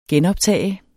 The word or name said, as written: genoptage